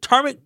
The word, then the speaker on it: turmeric: I'm taking turmeric ginger shots. – Wow. Try again. Turmeric. Sound it out. – Turmeric. How you spell it? – Turmeric. It's turmeric. – T-U-R-M-E-R-I-C.